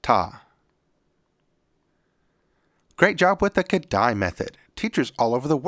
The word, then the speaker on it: Kodály: today's word is Kodály.